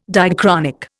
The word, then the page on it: diachronic: Indian